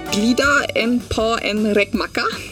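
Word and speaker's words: glida in på en räkmacka: Glida en po en rekmaka. – Sehr professionell ausgesprochen.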